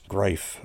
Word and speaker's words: Greif: That was Randy Greif on tapes and playing several instruments. Greif, which is spelled like grief, except the I and the E transposed.